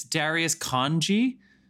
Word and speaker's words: Darius Khondji: It's Darius Khondji.